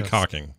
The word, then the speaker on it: caulking: And it's also that screwy word, caulking. It's pronounced, or it's spelled caulking, but no one says that. – Caulking. – They all say caulking.